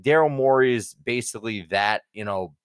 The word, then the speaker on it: Morey: Did I pronounce that one there? – Yeah, Morey, you nailed it, Dave.